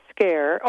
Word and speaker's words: Scaer: It's Dr. Robert Scare. All of this is on my website, on my resources page, by the Sweet. – Way. But his I think it's spelled S-C-A-E-R, and it's called The Body Bears the Burden.